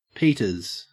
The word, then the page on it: peters: Australian English